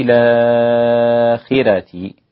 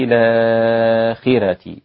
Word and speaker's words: الآخرة: كلمة الآخرة عند الإمام ورش ينطقها بالنقل هكذا، وفي الآخرة، والراء عنده رقيقة عشان الخاء مكسورة، تمام؟ يبقى دي قاعدة ورش، الراء رقيقة عشان التي قبلها مكسور، وبيمد أو بينقل هنا ويقول وفي الآخرة هكذا.